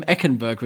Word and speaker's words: Eichenberg: Also über eure rechte Seite, äh, bei uns links, da steht nämlich, äh, da steht Austin Jackson, da steht Liam Eichenberg, äh, äh Eichenberg, Eichenberg, äh, äh, nee, Eckenberg, genau so. Liam Eckenberg wird er ausgesprochen.